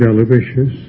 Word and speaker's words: Aloysius: Saint Aloysius, Gonzaga is the correct pronunciation, the first syllable,